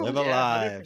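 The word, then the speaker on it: Live A Live: I'm under the wire, so I think I'm going to pronounce this incorrectly, but is it Live Alive? – You got it. That's Live Alive.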